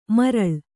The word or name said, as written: ಮಾರಾಳ್